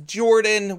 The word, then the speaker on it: Jordan: My name is Jordan with a silent PH in the middle, and I am joined always by my wonderful co-host, Sir Lucian, over at Sir Lucian Gaming.